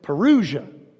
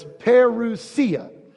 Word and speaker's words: parousia: Sometimes in America, we pronounce it parousia, but it's parousia.